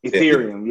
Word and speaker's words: Ethereum: What is this? I don't want to say this wrong. – Is that Ethereum? – Ethereum, yeah.